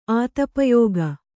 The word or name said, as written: ಆತಪಯೋಗ